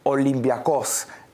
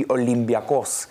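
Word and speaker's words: Olympiacos: In Greece, it's pronounced Olympiakos. Almost like with a slight B. It's not a P, it's a B. Olympiakos.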